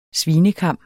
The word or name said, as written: SVINEKAM